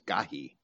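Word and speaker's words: Gai: And dropping the word for son, which is the ben or bene, that would be Gahi Hinnom, or Gehenna, Gehenam, eliding the first diphthong, which is AI, which I would pronounce as Gahi, and dropping the M, where we have Gehenna, from Gahi Hinnom.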